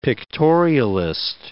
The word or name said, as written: pictorialist